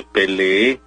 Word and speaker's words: peleé: Incluso en tiempo pasado el verbo pelear es complicado porque muchos dicen me peleé, por aquella cuestión de la articulación. Pero lo correcto es me peleé con doble E y con tilde, con acento en la E final.